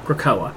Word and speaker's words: Krakoa: And their basic goal is that they're making that island, that mutant island that they live on, Kuroka. How do you say Krakoa. – It? Krakoa.